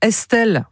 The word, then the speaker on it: Estl: Une nouveauté, elle s'appelle Estelle. Alors, ça s'écrit E-S-T-L.